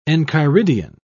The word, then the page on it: enchiridion: British English